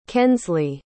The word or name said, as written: kenzley